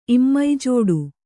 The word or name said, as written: ಇಮ್ಮೈಜೋಡು